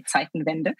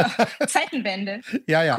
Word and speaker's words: Zeitenwende: So the Zeitenwende, as you pronounce it, we say Zeitenwende.